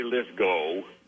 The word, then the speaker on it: Lithgow: And do you prefer Lithgow or Lithgow? Is there a specific pronunciation? – Well, I say Lithgow.